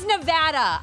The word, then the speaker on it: Nevada: That's not how you say it. – Thanks for having me. – Will Hill, everybody. It's Nevada. I need to put a PSA out there. Ladies and gentlemen, Nevada.